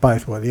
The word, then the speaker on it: Bythewood: Bythewood? – Bythewood, yeah.